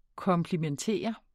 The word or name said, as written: komplimentere